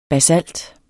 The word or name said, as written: basalt